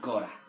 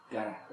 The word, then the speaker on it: gotta: Got to, que lo mezclamos, que suena gora. – Gora. – It's a price you gotta pay. Gara, gara, perdón. Gora también, me parece que también se dice, pero gara. Suena mejor. Se escribe G-O-T-T-A, es informal, pero es muy común.